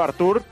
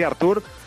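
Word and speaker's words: Arthur: Por ejemplo, Artur, que es como él dice que hay que pronunciarlo y por lo tanto lo voy a intentar pronunciar siempre así, Artur.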